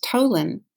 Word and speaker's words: Tolin: I spell it L-E-S, like Sam, L-E-I-G, like George, H, like Henrietta. And my last name is Tolin, T like Taylor, O-L-I-N, like Nancy.